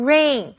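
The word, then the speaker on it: reign: 这家公司会推出相当容易操作的绘图软体。接下来我们看的是 rain 这个动词，注意 EI 在这里发 A 的音念成 rain，跟下雨的 rain 的发音是一样的。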